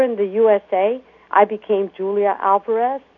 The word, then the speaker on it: Julia Alvarez: Of course, since I'm Dominican-American, half and half, it's always nice to have it both ways, my short American name pronounced the Spanish way, Julia Alvarez.